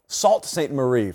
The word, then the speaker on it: Sault Ste. Marie: Sault Ste. Marie from my friends in Fortson.